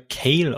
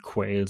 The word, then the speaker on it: Quayle: Äh, der wird in der Folge wird er Kale ausgesprochen. Kale. Also der wird mit Q-U-A geschrieben. Okay. Das geht aber in der Folge unter. Ähm, aber die korrekte Auszahl müsste schon Quail sein.